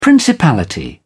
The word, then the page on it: principality: British English